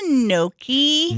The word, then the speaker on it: gnocchi: Yeah, I'll have the gnocchi. – Gnocchi? Gnocchi? – Sorry, ma'am, you're not saying it right. It's actually gnocchi.